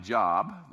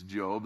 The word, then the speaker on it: Job: It's spelled like Job, but it's Job.